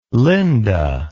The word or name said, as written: lynda